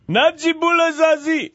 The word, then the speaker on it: Najibullah Zazi: Najibullah Zazi.